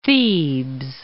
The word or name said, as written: Thebes